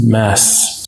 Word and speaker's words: Mads: Oh, I guess you don't pronounce the D in his first name. It's pronounced Mass.